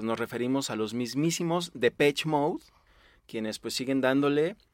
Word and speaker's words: Depeche: Sí, y la pronunciación también puede variar porque varios le dicen el Depeche, Depeche Mode y bueno, si se van al, origen del nombre que ahorita vamos a aclarar, pues sí, es en, en francés y esa sería la pronunciación más atinada.